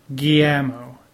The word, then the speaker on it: Guillermo: I'm never going to say it right, so you don't need to. – You can say it right. Guillermo. – Guillermo del Toro.